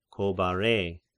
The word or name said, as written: Chobarei